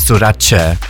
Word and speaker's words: Sorace: It's like Versace. Versace is pronounced Versace. Sorace.